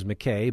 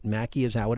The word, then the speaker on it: Mackey: To look at his name, you would think it was McKay, but Mackey is how it is pronounced.